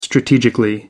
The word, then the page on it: strategically: American English